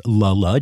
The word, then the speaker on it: Le Le: which I pronounced wrong when I announced it. It is pronounced Lola, despite being spelled L-E-L-E.